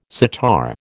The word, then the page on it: sitar: American English